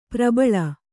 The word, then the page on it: ಪ್ರಬಲಿ: prabali; noun